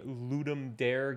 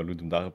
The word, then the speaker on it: Ludum Dare: And do you participate in every Ludum Dare game jam, if I'm saying that right? – Yeah, we try because we enjoy it.